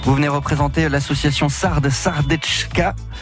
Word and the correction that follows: Sardeshka: Si je prononce bien. – Sardeshka. – On va y arriver. Sardeshka, puisque vous organisez ce week-end, samedi plus précisément, un atelier apéro, une pincho party.